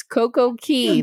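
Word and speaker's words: Coco Cay: Castaway, I don't care how Royal Caribbean says it. It's Coco Key. – Both pronunciations are correct. – No, that's how the Bahamians would say it. They say Key.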